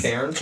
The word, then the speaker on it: cairns: Cairns. – Cairns. – C-A-I-R-N-S. – Yeah. – I think we pronounce it cairns. – C-A-R.